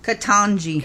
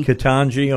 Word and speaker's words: Ketanji: I will tell you that, is her first name pronounced Ketanji? Is that right? Yeah. What is it? – Ketanji. – Ketanji, okay.